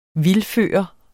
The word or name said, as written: vildføre